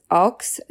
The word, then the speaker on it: Aukes: Marilyn, known as Aux Stower, it's spelled A-U-K-E-S, July 19th, 1941 to March 13th, 2021, Buffalo Center.